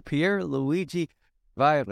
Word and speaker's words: Pierluigi Vajra: I'm so blessed today to talk with my guest, Father Pierluigi Vajra.